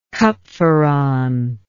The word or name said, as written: cupferron